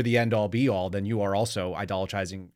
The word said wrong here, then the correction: idolizing: How do you say that word? Idolatrizing? – Idolizing. – Idolizing. Yeah. Thank you. I added an extra syllable for no reason.